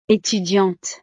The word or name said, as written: etudiante